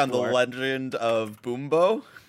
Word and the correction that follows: Bumbo: Boombo is spelled B-U-M-Bumbo. Bumbo. I like Bumbo. – The Legend of Bumbo. All right. – B-U-M-B-O.